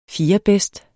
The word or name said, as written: fjerdebedst